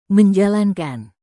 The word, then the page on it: menjalankan: Indonesian